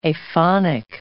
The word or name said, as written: aphonic